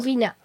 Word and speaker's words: Furina: Also für alle, die uns in ARD Sounds hören, Furina, heißt, habe ich es richtig gesagt? – Furina. – Okay. – F-U-R-I-N-A.